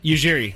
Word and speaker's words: Ujiri: I get where, how do you say his last name again? – Ujiri.